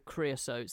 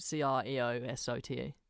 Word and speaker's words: Creosote: I'm not sure how it's spelled, but it's Mr. Creosote, Creosote.